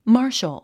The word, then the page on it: martial: mάː r ʃəl; マーシャル